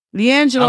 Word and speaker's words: LiAngelo: LiAngelo, is that how you say his name, these nuts? LiAngelo. – I don't know who these nuts is, but LiAngelo Ball.